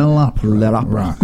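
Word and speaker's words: L'Opéra Rock: and Mozart L'Opera Rock. – Mm-hmm. – Did I say that right with some flair there? – I think that sounds good to me. – L'Opera Rock, right?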